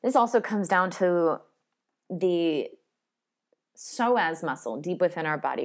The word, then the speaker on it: psoas: the psoas muscle. There's a silent P in there if you want to Google it, P-S-O-A.